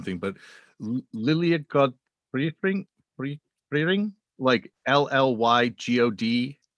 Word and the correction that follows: Llygod Ffyrnig: No, I mean, Llygodfyrnig, and the great anthem, NCB.